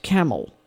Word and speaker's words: Campbell: They say that James might have pronounced Campbell, Camel, and the residents simply followed suit. So apparently, the name in Scotland and Ireland is pronounced Camel.